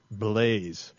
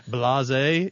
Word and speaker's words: Blaise: Blaise. – Was it really? – Blase is how you would normally pronounce it, but since it's French, it's Blaise. No, no, Blaise is how to say it.